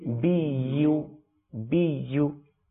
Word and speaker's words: زرابي: بيو بيو تو ايكتر اي ايكتر অনেক সময় আমরা ভুল করে থাকি, অর্থাৎ ইয়ার উচ্চারণটা হামজা এর মতো করে থাকি, বি ও বি ও, এটা হচ্ছে ভুল উচ্চারণ। সঠিক উচ্চারণ কি? বি ইউ বি ইউ, মনে থাকবে? – জি।